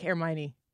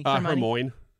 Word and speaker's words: Hermione: I think I find myself being a little bit more annoying, like Hermione. Is that how you say your name? Hermione? – Hermione? – Hermoine.